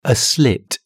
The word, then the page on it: a slit: British English